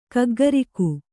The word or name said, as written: ಗಗ್ಗರಿಕ್ಕೆ